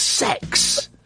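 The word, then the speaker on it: CeX: I'm being told by an ever-growing number of people that Kex Computer Exchange is pronounced as sex.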